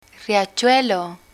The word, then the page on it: riachuelo: spanish